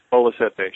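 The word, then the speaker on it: Bola Sete: Talk a little bit about that, the association with Cal Tjader, and then later on with Bolasette, is that how you pronounce his name, the great Brazilian guitarist? – Yes, Bolasette, sure. Uh-huh.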